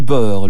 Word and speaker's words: Boer: La guerre des Boers, le 11 octobre 1899, les Britanniques entrent en guerre contre ces Boers en Afrique du Sud. Les Boers, d'un nom hollandais qui signifie paysan et se prononce boer en fait, sont les descendants des Hollandais et Français qui se sont installés autour du Cap de Bonne-Espérance au XVIIe siècle.